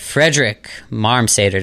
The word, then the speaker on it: Fredrik Marmsater: And today with me, I have Fredrik Marmsater.